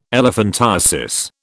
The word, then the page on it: elephantiasis: British English